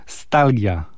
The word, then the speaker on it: Stalgia: Chociaż wszystkie tytuły są w języku angielskim, to jednak chyba Stalgia, tak powinniśmy wymawiać tytuł także całego albumu.